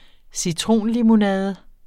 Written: citronlimonade

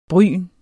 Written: bryn

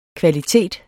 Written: kvalitet

